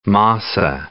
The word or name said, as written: masa